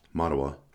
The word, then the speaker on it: Mattawa: How do you say that? M-A-T-T-A-W-A. Matawa. – Matawa. – Matawa. Okay.